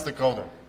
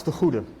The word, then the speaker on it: de Goede: Hans de Goede. – Hans de Goede. – De Goede. – But it's just Hans de Goed also works. As in the good, the bad, and the ugly.